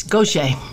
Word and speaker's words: Gauthier: It's Gauthier, correct? Yeah, I'm born in New Orleans. It's a Louisiana uh, pronunciation of a French name.